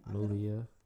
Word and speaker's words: Malia: Malia.